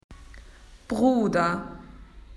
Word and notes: brother: Australian English

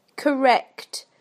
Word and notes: correct: British English

